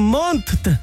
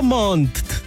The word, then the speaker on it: Montt: Si eres de Puerto Montt o alrededores, no te puedes perder este hermoso panorama, los temporales internacionales de teatro de Puerto Montt. – Bien, es que hay que pronunciar las dos T.